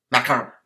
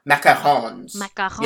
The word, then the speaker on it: macarons: And I love macaroons. Macarons. – Macarons.